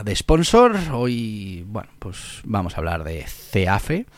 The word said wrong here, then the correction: CEFAE: Eh, perdón, de CEFAE, que siempre lo digo mal,